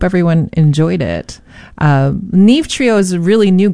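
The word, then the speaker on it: Neave: That's Neve spelled N-E-A-V-E. I've been wondering how to pronounce that.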